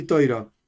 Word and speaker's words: Toira: ¿Por qué se pronuncia Shabes y Toira? Pregunta Felipe. Porque yo tengo una pronunciación ashkenazí. Y dentro de la pronunciación ashkenazí, hay un video en el canal sobre este tema.